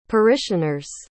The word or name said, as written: parishioner's